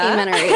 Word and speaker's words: amenorrhea: Amenorrhea. – Amenorrhea, yes.